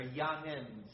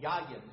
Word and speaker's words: yayin: The word used here is the word yayin, or yanin, sometimes they'll call it. But yayin is probably the correct pronunciation.